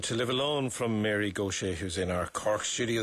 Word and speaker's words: Gauthier: And it's spelled T, because I keep on being tempted to say Gauthier, G-A-U-T-H-I-E-R, but it's the Cajun pronunciation is Gauthier. That's right. – How it's pronounced, yeah.